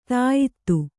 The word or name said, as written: ದ್ಯುತಿ